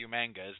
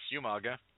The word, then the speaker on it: Umaga: Actually, Umanga. William Regal, for months, pronounced it as Umanga because with the spelling in the in traditional Samoan, that's the correct pronunciation, but no, it's Umaga.